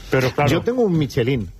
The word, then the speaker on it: Michelin: Michelin. A ver, aquí en la Península Ibérica hacemos Michelin.